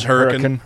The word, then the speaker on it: Hurricane: It's but the local pronunciation is Hurricane.